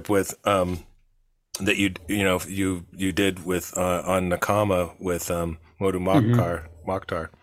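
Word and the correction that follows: Mdou Moctar: How do you say his first name? – Mdu Mokhtar. Yeah.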